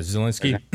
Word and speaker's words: Zelensky: Zelensky, is that how you say his name? – Yeah, Ukraine, the president. – Yeah, Zelensky. I mean, he's came and said nothing about it.